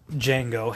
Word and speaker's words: Django: That, of course, asks Django how it's spelled. And he spells it right out and tells him the D is silent.